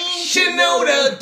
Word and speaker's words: Shinoda: Mike Shinoda.